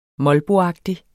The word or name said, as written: molboagtig